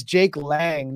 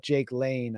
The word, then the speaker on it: Jake Lang: You got to remember Jake, and it's Jake Lang, not Jake Lane.